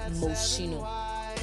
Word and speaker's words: Moschino: It is actually Moschino.